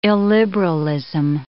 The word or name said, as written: illiberalism